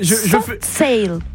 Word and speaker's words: Soft Cell: Comment prononce? J'appelle... – Soft cell. – Comment? – Soft cell. – Soft cell. C'est mieux prononcé, Michel, on est d'accord.